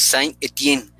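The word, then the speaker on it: Saint-Étienne: De hecho, si es complicado mencionar en inglés, pues ya va a ser en francés, pero es Saint Etienne. Es la pronunciación correcta, Osvaldo.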